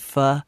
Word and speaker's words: pho: It's called pho. It's spelled P-H-O.